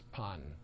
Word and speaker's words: Bon: They had its own particular version of shamanism called Pan, spelled in English B-O-N, pronounced Pan, like more like a P.